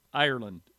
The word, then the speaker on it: Ireland: And I say, John, J-O-H-N, Ireland, like the country.